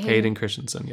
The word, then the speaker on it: Hayden Christensen: That's how you say his name, right? – Hayden Christensen, yes.